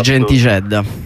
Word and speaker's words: Gentixedda: Allora sì, intanto il titolo è Gentisedda, scritto Gentixedda, ma si legge esatto. Gentisedda. – Sì, perfetto.